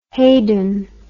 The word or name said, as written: Haydon